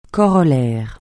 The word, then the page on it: corollaire: francais